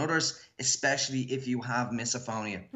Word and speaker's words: misophonia: Tell me, what is, I hope I pronounce this right, misophonia, is that what it is? – Misophonia, yeah.